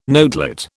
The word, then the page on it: notelet: British English